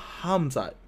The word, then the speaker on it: Khamzat: And he's fighting this guy named Khamzat. The K is silent. The H is hard.